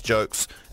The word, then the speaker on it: Jolks: Anyway, my show's called Just Jokes. Spelled J-O-L-K-S. Because it rhymes with yolks and folks.